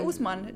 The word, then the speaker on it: Ousmane: Men du sa Ousmane, det låter väl korrekt.